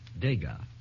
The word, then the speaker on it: Degas: I hate to correct your pronunciation, but it's Degas. – Degas. – There's no... – Claude it is Degas. Degas. – It's how? – There's no accent on the E, I think, Mr. Hart. – The accent is on the last syllable.